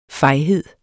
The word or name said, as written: fejhed